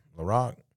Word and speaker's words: LaRocque: And then the next guy, you know, Kevin LaRocque.